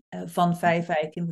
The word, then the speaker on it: van Vijfeijken: van Vijfeijken, which is difficult to pronounce, but in Dutch, it literally means I am called Tosca of the Five Oak Trees.